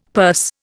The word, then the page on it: purse: Indian